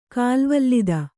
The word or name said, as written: ಕಾಲ್ವಲ್ಲಿದ